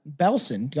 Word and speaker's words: Belsen: Dub the Beast of Blestien. – Belsen. – Belsen.